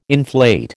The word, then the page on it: inflate: American English